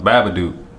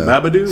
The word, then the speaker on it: Babadook: Actually, one movie I had seen, another one my girl saw, but I had saw The Babadook.